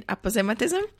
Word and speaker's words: aposematism: Um, I say aposematism. – Okay, great. I think that is more correct.